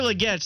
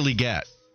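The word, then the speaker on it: Legette: On text line, he's saying, I think you still pronounce it like Leggett. Enunciate the get, but you have the lead.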